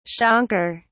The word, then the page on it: shankar: British English